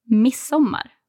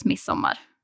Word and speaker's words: Midsommar: So do you not pronounce the D? – Not really. I mean, you can if you want to be correct and pronounce it perfectly the way you should. But everyone says midsommar.